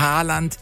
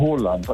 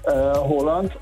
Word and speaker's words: Haaland: Erling Haaland ist falsch, ne? – Ja, das ist absolut falsch. Er wird ausgesprochen Haaland, also mit O. – Ja. – Haaland und zwei A's werden als O ausgesprochen und ein A mit einem Kringel oben drauf ist eigentlich auch eine Aussprache, ein O.